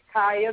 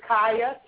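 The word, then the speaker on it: Kiah: My Instagram name is That Lady Barber, and my Facebook name is Kaya, that's K-I-A-H, Snailville's Finest Barber. And that's it. – Say your name again for me. – It's Kaya, K as in kite, I as in ice, A as in apple, H as in house. Kaya.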